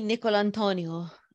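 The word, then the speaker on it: DiNicolantonio: Dr. James, what's it? Nicotiano? – Dr. James D. Nicolantonio.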